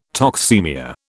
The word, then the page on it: toxaemia: British English